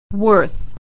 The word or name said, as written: worth